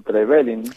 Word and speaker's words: Trevelin: ¿Trevelin o Trevelin? – No, muy bien pronunciado. Trevelin, pueblo del molino. Trepueblo, Belin, molino.